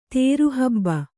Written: ತೇರುಹಬ್ಬ

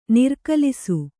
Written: ನಿರ್ಕಲಿಸು

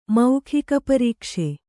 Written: ಮೌಖಿಕಪರೀಕ್ಷೆ

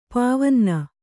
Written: ಪಾವನ್ನ

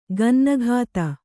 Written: ಗನ್ನಗಾತಿ

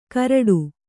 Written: ಕರಡು

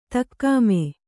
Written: ದಗಮು

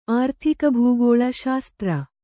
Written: ಆರ್ಥಿಕಭೂಗೋಳ ಶಾಸ್ತ್ರ

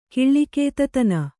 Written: ಕಿಳ್ಳಿಕೇತತನ

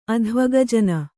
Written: ಅಧ್ವಗಜನ

